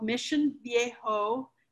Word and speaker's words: Mission Viejo: So I was homeschooling, and we were here, and I live in a little bedroom community called Mission Viejo, and that's how you say it, Mission Viejo, not Mission Viejo, no.